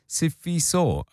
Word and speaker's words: Sifiso: And I mean, the name Sifiso is not a difficult name to say. It's three syllables, Sifiso.